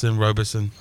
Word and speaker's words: Robeson: Robeson.